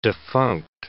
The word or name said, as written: defunct